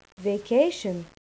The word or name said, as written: vacation